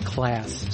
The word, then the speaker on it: Klass: Well, next we're going to play a record by Klass, K-L-A-S, called I Destroyed Your Love on Circle City. – That's Klass. – Sorry. Okay, Klass. – It's just, like it even says on the record, pronounced Klass.